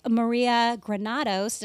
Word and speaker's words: Maria Granados: And today we are continuing the conversation with Maria Granados.